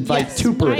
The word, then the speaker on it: vituperative: Vituperative. Is that correct? The pronunciation vituperative. – Yes, I think so. I just listened to a computer say it before I came.